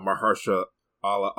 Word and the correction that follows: Mahershala Ali: Mahershala Ali.